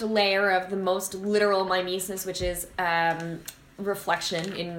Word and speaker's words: mimesis: So it's the, sorry, mimesis it's pronounced? – Yeah.